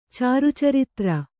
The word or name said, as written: ಚಾರುಚರಿತ್ರ